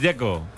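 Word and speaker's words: Džeko: De Z, ¿cómo se pronuncia? – Dzeko. Bruno Dzeko. Dice que es Dzeko.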